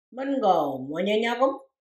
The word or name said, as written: mengawoom wanyanyagem